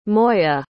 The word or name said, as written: moya